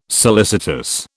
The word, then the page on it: solicitous: British English